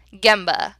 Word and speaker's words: Gemba: So first things first, Gemba. That's spelled G-E-M as in Mary, B as in boy, A, Gemba.